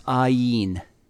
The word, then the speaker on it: ayin: It's pronounced ayin.